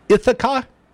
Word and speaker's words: Ithaca: And gentlemen, you're going to have to tell me if I'm pronouncing the name of the title of the movie right. Ithaca? – Yeah, that's it.